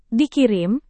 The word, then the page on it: dikirim: indonesian